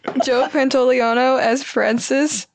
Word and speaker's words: Pantoliano: Pantoliano. – Wait. Oh, yeah. – Pantoliano.